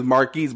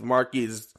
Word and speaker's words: Marquise: My name is Marquise, but people spell it like Marcus, or they say it like Marcus. It's like, bro, it's Marquise.